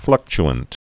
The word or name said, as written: fluctuant